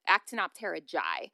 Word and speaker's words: Actinopterygii: So we've actually talked about the class Actinopterygii before, but I think I was mispronouncing it as Actinopterygii. But I actually looked at a phonetic outline of it. Actinopterygii.